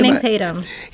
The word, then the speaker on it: Channing Tatum: Channing Tatum.